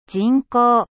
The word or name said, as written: 人工